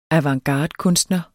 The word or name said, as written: avantgardekunstner